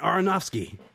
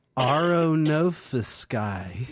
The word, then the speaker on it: Aronofsky: Darren Aronofsky. Fucking, I can't say his name. Aronofsky.